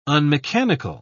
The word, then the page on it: unmechanical: British English